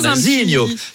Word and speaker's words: Nazinho: C'est vrai que c'est-à-dire que ça se prononce. – Ça se prononce avec l'accent chantant. Nazinho.